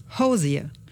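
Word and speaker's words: Hozier: Ich habe mal nachgeguckt und gehört, wie er das selbst sagt auf YouTube und er sagt, ich bin Hosier. Also sage ich das auch. Hier kommt jetzt Hosier aus Irland.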